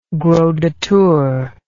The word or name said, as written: gros de Tours